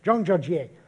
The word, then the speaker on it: Zhangjiajie: Zhangjiajie in the Hunan province of China.